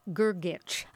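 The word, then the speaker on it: Grgić: As soon as I saw that name, it's spelled G-R-G-I-C. – Uh-huh. – Only it's not an American. – You put a C-H on the end of it.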